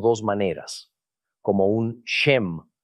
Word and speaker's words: shem: El mover el pequeño punto localizado encima del carácter a la derecha del carácter del abecedario hebreo lo hace un shem que nos da un sonido esh, como Sharon. El mover el pequeño punto a la izquierda hace el mismo carácter de sem, dándonos el sonido de la S, pronunciado como sam.